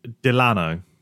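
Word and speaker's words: Dilano: Was the sad death of, I've got it up here because I don't want to say his name wrong, Dilo Vantoff? – Delano. – Delano, sorry. Delano Vantoff. – We'll go with Delano. I'm 99% sure.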